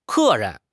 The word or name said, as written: ke4 ren2